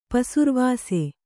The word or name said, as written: ಪೆಸರ್ವಾಸಿ